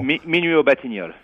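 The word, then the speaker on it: Minuit aux Batignolles: It's called Minute au Batignolle. I probably butchered that. I apologize if I did. Minute au Batignolle. – Minuit au Batignolle.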